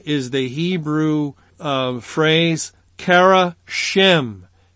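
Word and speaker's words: Shem (שֵׁם): And the word translated as renown, the Hebrew word, is Strong's number 8034, and it is the word that I would pronounce as Shem. Shem.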